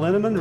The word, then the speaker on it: Linneman: Val Linneman, Rhymes with Cinnamon,